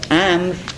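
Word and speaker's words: Amr (عمرو): And the word Amr, let me show you that Amr is written here. It has a V at the end, a W at the end, which is not pronounced, which is silent, mute. It is just to help people to read it and not to mistake it for Omar.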